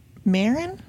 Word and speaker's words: Maron: Um, and I finally settled on one by Margaret Maron. Is that how you'd say her name? – M A R O N.